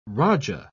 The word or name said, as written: raja